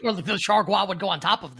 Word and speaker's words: Chargois: Is that really what it is? – No, but it's Chargois. Chargois? – Is it ever like Gua? That would be amazing. – Yeah, Chargois. – Jake and Tyler definitely know how to pronounce this guy's name. – I think Jake does. Jake, how do you pronounce this guy's name, this reliever for the Marlins right now? – It is Chargois.